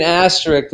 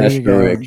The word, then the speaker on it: asterisk: there should be an asterisk though by that championship, if you ask me. – An asterisk, please pronounce it correctly. Uh, okay, an there asterisk. You go. There you go that had to be a thrill of a lifetime.